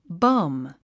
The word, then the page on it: bum: bʌ́m; バァム